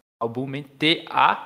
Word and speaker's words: Albumentations: Existe uma biblioteca muito legal, você que está trabalhando com isso e usa Python, que ela se chama Albumentations. É, se escreve Albumen, T-A-T-I-O-N-S, Albumentations.